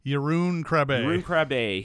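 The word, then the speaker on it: Jeroen Krabbé: It's you know, they're trying to buy guns from Joe Don Baker, and there's this whole thing where the Krabbe, how do you say you pronounce his last name? – Jeroen Krabbe, his character is defecting, which ends up kind of being like a double cross kind of scenario, where that mm-hmm.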